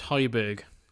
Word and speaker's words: Højbjerg: How do you pronounce that? – I think it's Højbjerg. – Højbjerg, yes. So that move by Jolinton